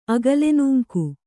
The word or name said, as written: ಅಗಲೆನೂಂಕು